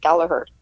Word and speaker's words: Gallagher: And one other thing I noticed is you haven't pronounced a second G in Gallagher. Nobody knows that.